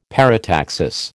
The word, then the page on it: parataxis: American English